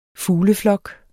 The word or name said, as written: fugleflok